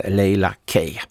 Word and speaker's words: Laila Kay: Men hade han lyssnat på hur Kaj uttalade hennes namn innan låten kom igång, då kanske han också hade sagt korrekt Laila Kay.